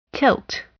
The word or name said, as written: tilt